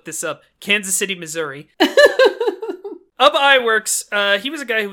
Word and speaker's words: Ub Iwerks: So Ub Iwerks, who I looked up YouTube videos how to pronounce it. I believe his name was in fact Ub Iwerks.